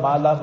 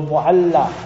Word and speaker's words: Mualla: We pronounce all upside down, Mala, Mala, you know, like all the people are Mal or something. No. So Mualla. Mualla is that upper region.